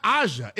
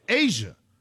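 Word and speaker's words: A'ja: You're not even saying it right. You're saying it's not Aja, it's Asia.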